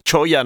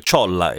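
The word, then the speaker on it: cholla: Adesso capiremo perché, cioè perché la parte del jumping, choya non lo so dire, so solo che si scrive C-H-O-L-L-A e quindi si legge choya se leggete la doppia L alla spagnola come previsto, altrimenti potete leggere ciolla, e se magari siete del sud vi fa ridere perché assomiglia a qualcos'altro.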